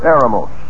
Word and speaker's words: eremos: Now, the word wilderness, if you look it up in the Greek, is the word eremos. E-R-E-M-O-S, we would spell it in English, which means literally desert, or a solitary or a lonely place.